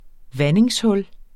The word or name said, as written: vandingshul